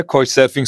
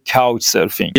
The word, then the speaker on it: Couchsurfing: اگه بخوام درستتر تلفظش کنم و یه خورده شیکتر باید بگم که کاوچ سرفینگ یه جورایی تو خارج از ایران به هر کی میگی کوچ سرفینگ اصلا کسی متوجه نمیشه